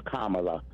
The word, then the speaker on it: Kamala: It is Kamala. – Yeah. – Just like Pamela. – That's what but with a K.